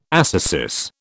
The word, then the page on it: ascesis: British English